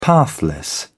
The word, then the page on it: pathless: British English